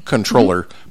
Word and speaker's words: comptroller: Controller because I'm kind of old school because I'm you're going for the technical correct pronunciation. – Yeah. Yes. Comptroller is what everybody sees, but yes. Yeah, controller.